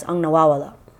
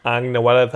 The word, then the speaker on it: Ang Nawawala: I would have been like, ang nawala that. – Close. Yeah. You got the first syllable right.